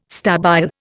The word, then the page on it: stabile: Indian